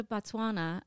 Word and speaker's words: Botswana: So by the time they go to Botswana, I hope I'm pronouncing that right.